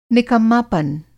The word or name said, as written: निकम्मापन